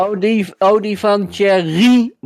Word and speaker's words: Thierry: Nou, zegt hij, het is Thierry met de klemtoon op Rie en dan Baudet.